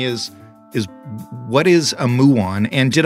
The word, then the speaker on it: muon: So it's called a muon um, after the Greek letter mu. Uh, hardly anybody knows what a muon is except for a few physicists.